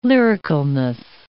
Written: lyricalness

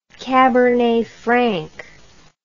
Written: cabernet frank

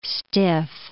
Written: stiff

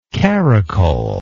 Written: caracoler

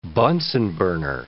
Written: Bunsen burner

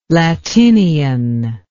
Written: latinian

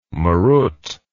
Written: marut

